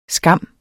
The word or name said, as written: skam